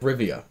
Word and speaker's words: Rivia: Yeah, Geralt of Rivera. – Riviera. – Yeah, the samurai of Rivera. – Did I say it right? – Rivia.